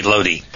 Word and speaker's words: Lodi: Lodi, in Italian, in a lot of Romance languages, I is pronounced E, so it should be Lodi.